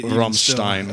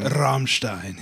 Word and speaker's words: Rammstein: And I'm sure I'm not pronouncing that even right. – Rammstein. – Rammstein. Yes.